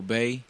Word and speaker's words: Bey: I said that before, Indigo Bay. Bay is spelled B-E-Y.